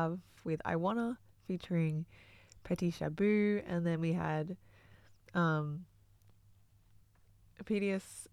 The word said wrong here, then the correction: Oedipus: Who is it? How do you pronounce it? – Oedipus. – Oedipus, that's it.